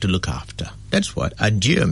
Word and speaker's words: adieu: If you look at another language, there is a similar word in French called adieu, A-D-I-E-U, adieu, and it's pronounced adieu, where the word a and dieu means God.